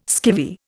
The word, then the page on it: skivvy: Indian